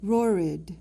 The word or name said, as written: rorid